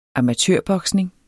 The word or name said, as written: amatørboksning